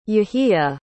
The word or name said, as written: yehia